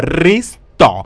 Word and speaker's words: resto: Ay, no, pelotuda, restaurant, no, resto. Con acento en la O.